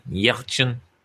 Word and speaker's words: Yelchin: Anton Yelchin, is that how you say his name? – Yelchin.